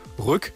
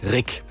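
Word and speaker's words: Ryck: Und hier in Greifswald haben wir einen Fluss, der wird auch gerne mal Rück oder Riek ausgesprochen, der heißt aber Rick.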